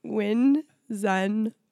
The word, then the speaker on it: Winzenburg: Not because it's hard to say, but because I always said like Winzenbacher or something, and nobody ever said, no, dude, it's Winzenburg.